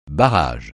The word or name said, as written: barrage